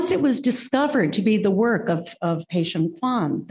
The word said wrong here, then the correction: Tian Peixian: am I saying that right? – Tian Peixian. – Oh my God, I've got it all wrong.